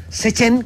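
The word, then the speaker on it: CCHEN: Muchísimas gracias al doctor Enrique Mejías Barrios, investigador en temas apícolas de la Comisión Chilena de Energía Nuclear, CECHEN, como me dijeron que se pronunciaba para que no me reten.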